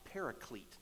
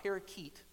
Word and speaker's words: paraclete: It sounds like the word that we sometimes use to describe the Holy Spirit, a paraclete, not parakeet, right? Not the bird that's on the shoulder, right? Not that. That was for the birds, sorry. But paraclete, meaning our helper.